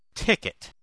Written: ticket

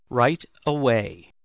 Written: right away

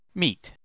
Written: meet

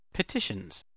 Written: petitions

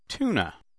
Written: tuna

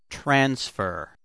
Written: transfer

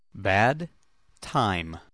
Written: bad time

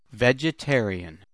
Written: vegetarian

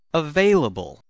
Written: available

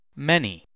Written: many

